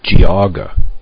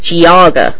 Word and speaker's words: Geauga: I believe it is pronounced Geauga. – I'm telling you, it's Geauga.